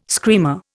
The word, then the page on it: screamer: Indian